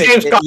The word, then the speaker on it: Gamescom: So for this week's main course, we are going to talk about Gamescom Live. Hopefully I said that right. I'm gonna say GamesCon. – It was beautiful. No. – It is GamesCon. I read it like six times.